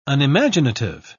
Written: unimaginative

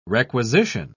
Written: requisition

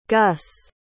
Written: gus

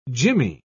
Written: jimmy

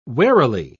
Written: warily

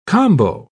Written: combo

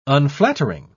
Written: unflattering

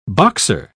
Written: boxer

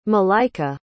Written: maleika

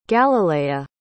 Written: galilaia

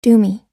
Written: doomy

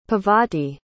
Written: Pavati

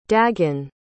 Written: dagen

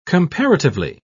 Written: comparatively

